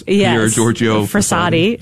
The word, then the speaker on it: Pier Giorgio Frassati: People trying to pronounce Pier Giorgio Frassati. – Okay. Was apparently. – Did I get it right, by the way? – Yes, you did.